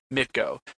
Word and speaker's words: MTGO: They refer to it as Mitgo. MTGO, literal pronunciation.